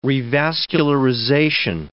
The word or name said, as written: revascularization